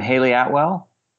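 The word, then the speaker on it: Hayley Atwell: Haley Atwell? – Yes.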